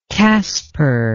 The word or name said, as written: kasper